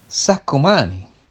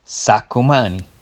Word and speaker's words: Saccomani: Ah, Saccomani, con la doppia C e la singola lettera N. Ho capito, Saccomani.